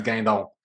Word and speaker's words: Guindon: Was that better or worse than Guindon? So you don't really have to pronounce the U, but you just like kind of go straight to the I. It's Guindon.